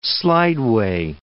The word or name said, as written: slideway